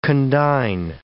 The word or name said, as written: condign